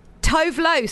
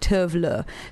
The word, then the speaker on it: Tove Lo: Tove Lo, some people call her, but it's actually pronounced Tove Lo.